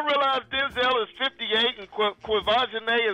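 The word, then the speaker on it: Quvenzhané: Please tell me y'all saw a cute little 9-year-old Quivisione. Is that how I say her name, Sybil? – Quivisione. Yeah, you got it.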